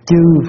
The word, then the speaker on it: chư: trừ phiền não. Nhưng mà thật sự cái câu đó phải đọc là nguyện tiêu tam chướng chư phiền não, các thứ phiền não.